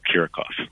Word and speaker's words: Kiracofe: No, you got it exactly right. It's Philip Kiracofe.